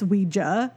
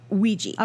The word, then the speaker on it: Ouija: So, but fun fact, Peters allegedly is the person responsible for the name Ouija, which technically I'm mispronouncing this entire time. It's Ouija. Apparently, does it go back and forth? – I will say, well, modern pronunciation, which is Hasbro, who now currently owns it, is Ouija.